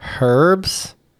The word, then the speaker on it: herbs: We are talking about herbs. I believe we landed on, or we're pronouncing the H.